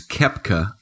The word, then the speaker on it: Koepka: But my best of the week was Brooks Koepka. I believe okay. I'm saying yeah, I think you're right. right. I think you're saying it right.